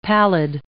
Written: pallid

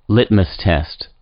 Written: litmus test

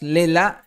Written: LELA'

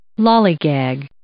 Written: lollygag

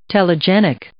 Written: telegenic

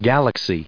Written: galaxy

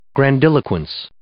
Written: grandiloquence

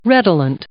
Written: redolent

